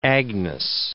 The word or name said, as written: Agnes